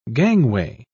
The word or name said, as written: gangway